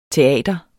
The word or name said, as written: teater